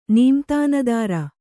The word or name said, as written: ನೀಮ್ತಾನದಾರ